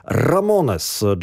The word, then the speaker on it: Ramones: Jak to się wymawia? Punkowcy zawsze mówili po prostu Ramones.